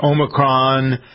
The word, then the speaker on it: Omicron: Number one this week is Omicron, the potential. – By the way, you've nailed that pronunciation.